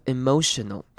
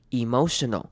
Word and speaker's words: emotional: 那今天第一个重点呢是 in terms of，这边 terms 后面的 s 的声音要念 z，然后跟 of 连在一起，所以会念成 in terms of， in terms of， in terms of， in terms of emotional。好，看到这个字可以念成两种念法，第一种念法是把 e 发长母音 e 的声音念成 emotional， emotional，或者是你把这边念成轻音 emotional。 Emotional 两种声音都是听得懂的。